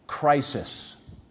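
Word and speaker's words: Crisis (κρίσις): And we can miss it because even the way it's spelled in our Greek testaments, when they transliterate it, they spell it with a K. But you know what the word is for judgment? Crisis. With a K. But crisis. So we get our word, I'm in a crisis, from this word judgment.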